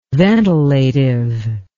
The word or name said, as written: ventilative